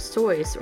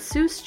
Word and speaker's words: Seuss: The nickname was originally pronounced Sois or Zois, but Seuss changed the pronunciation to a more recognizable one used today because it sounds more childlike, similar to Mother Goose.